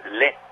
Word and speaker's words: Lai: Y la interpreta la orquesta, o la interpretó la orquesta de Francis Lai. Se pronuncia Le, un compositor francés tristemente desaparecido.